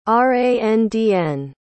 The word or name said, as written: randn